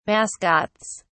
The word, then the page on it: mascots: British English